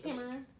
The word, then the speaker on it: Cameroon: And then is it Cameroon? If I said that correctly? – Cameroon.